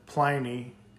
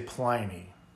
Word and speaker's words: Pliny: And they're like, well, the owner of the company calls it Pliny, and he's the one that makes it. So, yeah, we say Pliny.